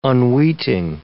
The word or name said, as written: unweeting